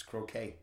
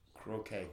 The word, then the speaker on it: croquet: It's croquet. – That's not how it's pronounced. – Are you thinking of crocheting? – Look it up in the Webster's Dictionary. It's pronounced croquet.